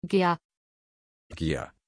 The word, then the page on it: giah: German